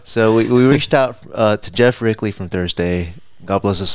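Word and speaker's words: Geoff Rickly: We actually reached out to Geoff Reekley. I didn't say it right. Geoff Reekley. – Uh-huh. – Geoff Reekley. Okay, there we go. Geoff Reekley. – Nailed it.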